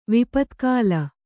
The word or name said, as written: ವಿಪತ್ಕಾಲ